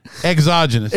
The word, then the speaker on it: exogenous: And so you're going to have these, you know, exogenous, you know, things that come out. – You didn't pronounce it right. How many times did I tell you? – Genius. – Exogenous. – Daggone it.